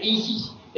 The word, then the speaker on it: Isis: Es el mantra de los misterios Chiapo, que se pronuncia Isis, en dos, se reparte en dos sílabas.